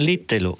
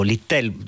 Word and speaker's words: Littell: Allora, Jonathan Little, o Littell, scegliete voi come pronunciarlo,